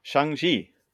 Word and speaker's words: Shang-Chi: Shang-Chi.